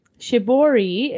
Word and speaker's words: Shibori: Shibori is a form of tie-dye which originated in Japan and has been practiced there since the 8th century.